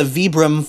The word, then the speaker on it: Vibram: They're pronounced the Vibram Five Fingers.